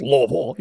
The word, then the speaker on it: Louisville: It's named after King Louis, but it's Louisville. Yes, no, you're right. Dude, you're fine. – Yeah, no, like, whatever. – Everyone who lives there pronounces it that way.